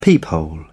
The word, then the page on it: peephole: British English